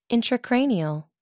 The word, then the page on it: intracranial: American English